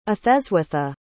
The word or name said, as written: etheswitha